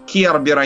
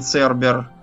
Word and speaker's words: Кербер: И правильнее, например, э, что еще говорить? Кербер, а не Цербер.